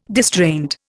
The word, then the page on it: distraint: Indian